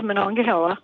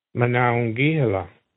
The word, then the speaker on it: Monongahela: It is Monongahela. Yeah, Hela. Yeah, Monongahela. – And in case you're all wondering, I used to live in Morgantown for like 15 years, and I pronounce it Monongahela, except when I pronounce it Monongahela. But technically, the word comes from the Lenape language.